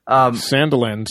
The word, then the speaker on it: Sandilands: Sandilands, yeah, that looks like it.